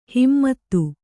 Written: ಹಿಮ್ಮತು

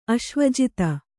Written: ಅಶ್ವಜಿತ

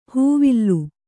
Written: ಹೂವಿಲ್ಲು

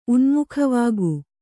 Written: ಉನ್ಮುಖವಾಗು

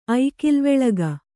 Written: ಐಕಿಲ್ವೆಳಗ

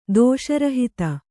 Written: ದೋಷರಹಿತ

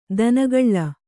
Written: ದಿನಗಳಿ

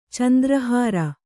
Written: ಚಂದ್ರಹಾರ

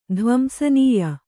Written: ಧ್ವಂಸನೀಯ

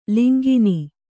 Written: ಲಂಘನೆ

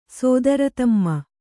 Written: ಸೋದರತಮ್ಮ

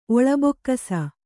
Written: ಒಳಬೊಕ್ಕಸ

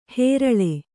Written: ಹೆರಳೆ

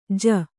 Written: ಜೂ

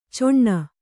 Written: ಚೊಣ್ಣ

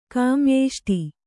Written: ಕಾಮ್ಯೇಷ್ಟಿ

